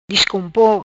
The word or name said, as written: Discompo